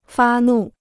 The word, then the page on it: 发怒: fā nù